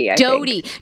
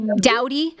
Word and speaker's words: Doughty: How do I say her last name? Doubt? – Kristen Doughty. – Doughty from Vanderpump Rules.